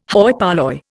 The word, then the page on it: hoi polloi: Indian